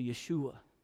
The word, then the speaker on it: Yeshua: You run to him and all that he is, then you come to Yeshua, which is the Hebrew pronunciation of Jesus, which is how Joshua would have been pronounced.